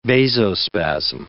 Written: vasospasm

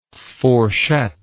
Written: fourchette

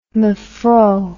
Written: mevrou

